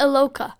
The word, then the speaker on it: Iloka: Maybe Iloka. – It's Iloka.